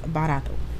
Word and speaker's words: barato: So like I said, the word is barato. – Barato. – And it rhymes with the word Naruto.